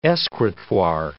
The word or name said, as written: escritoire